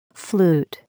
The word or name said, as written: flute